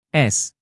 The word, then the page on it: s: Indonesian